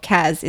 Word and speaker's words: Cas: And just so people know, my pronunciation of Cas is actually C-A-S, not C-A-Z.